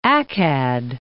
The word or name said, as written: Accad